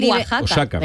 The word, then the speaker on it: Oaxaca: Sí, Oaxaca, pero se dice Oaxaca. – Eso. – Exactamente, con J, pero se escribe con X.